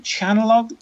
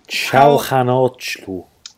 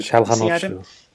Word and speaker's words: Çalhanoğlu: Tufan, and obviously the man that most people are very excited about at the moment, which is, how do you say him? Chanalog? – Çalhanoğlu. – There you go. – Çalhanoğlu.